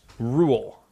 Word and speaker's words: Roald: It's actually pronounced Rule. – Ruled. – Roald Dahl? – Yeah, the D is silent.